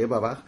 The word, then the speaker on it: Eberbach: Y sí, nazco en un pueblo que se llama Eberbach, se escribe Eberbach, se pronuncia Eberbach, y que, una vez más, me voy...